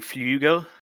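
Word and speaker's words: Flügel: I mean, if you actually pronounce the umlaut properly, it'd be Flugel, but whatever.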